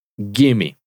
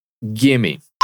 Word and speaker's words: gimme: De manera reducida, vamos a practicar su pronunciación. Repite. Gimme. Gimme.